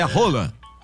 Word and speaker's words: Haaland: Segundo é o Haaland. O Roland. – Roland, porque a gente fala isso, o pessoal tem que entender. Ele mesmo ensinou a pronúncia correta do nome dele. É Roland.